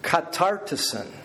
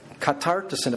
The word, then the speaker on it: katartisen: In the Greek it's pronounced this way, katartisen, katartisen.